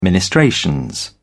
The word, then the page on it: ministrations: British English